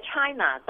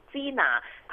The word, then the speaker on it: China: 是在去年十月十二号，新一届立法会议员就职宣誓，梁仲恒和游慧晶涉嫌将英文的 China 读成 Gina，他们宣誓的时候又展示 Hong Kong is not China，香港不是中国的毛巾，亦都引起港府和建制派人士不满的。